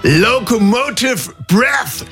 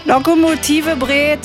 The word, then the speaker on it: Locomotive Breath: Also da bin ich gespannt, wie die das alle in Brandenburg so aussprechen. Äh, Lokomotive Bret. Brett.